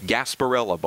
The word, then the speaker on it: Gasparilla: Gasparilla Bowl. – Right. – It rhymes with sarsaparilla, which is an old-timey soda drink.